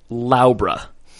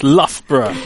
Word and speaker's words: Loughborough: I'm going to say Laubra. – I mean, you're not too bad. I've heard this pronounced Luga Baruga before, so. – No, you mean Loughborough. That's not it? – It's Loughborough.